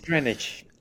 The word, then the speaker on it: Greenwich: Jij bent naar Connecticut geweest, naar Research at in moet ik ook Greenwich zeggen? Of is het Greenwich? Omdat het dan... – is Greenwich. – Ah, Greenwich, ja. Well, dat is de uitspraak.